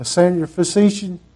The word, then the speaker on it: Syrophoenician: And the woman was a Greek, how do you pronounce that, Derek? A Syrophoenician? Did I say it right? There you go.